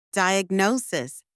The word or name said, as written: diagnosis